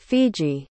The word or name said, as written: fiji